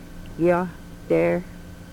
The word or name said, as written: Yohtar